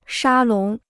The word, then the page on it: 沙龙: shā lóng